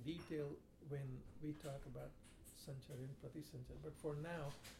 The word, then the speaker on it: Sanchara: The cycle of creation is called Sanchar. Sanchar is spelled S-A-N-C-H-A-R-A. Sanchar.